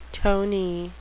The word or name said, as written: tony